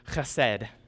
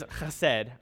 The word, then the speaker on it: chesed: And the Hebrew word is chesed.